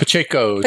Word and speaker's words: Pacheco: How do you say that? – Pacheco. – Pacheco? – Thiago Pacheco.